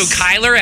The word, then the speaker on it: Cuyler: No, I'm going to say, is it a weird spelling like C-H-Y? – C-U-Y-L-E-R. – Okay. Oh, now, okay, I've seen it. I think I used to say it wrong.